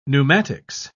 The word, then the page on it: pneumatics: British English